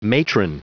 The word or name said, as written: matron